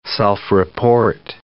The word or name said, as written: self-report